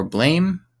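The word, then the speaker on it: BLAME!: Would be Blam or Blame. Okay. I think it's spelled Blame, but I think it's said Blam, like a gunshot.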